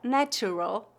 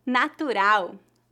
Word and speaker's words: natural: Natural, natural, natural. Tal como a palavra animal, a sílaba tônica da palavra natural é no final e a letra L é pronunciada como U, natural.